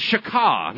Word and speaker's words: shachah: And the first one is shakah. Now, this is how it's spelled. S-H-A-C-H-A-H. Shakah. S-H-A-C-H-A-H.